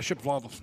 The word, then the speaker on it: Shapovalov: Dennis Shapovalov. – Yeah, Shapovalov. Mm-hmm. – Y'all heard of him before? – Yeah, Shapovalov. – Oh, who the heck is that? – He's a tennis player. – Sharapova. – Shapovalov.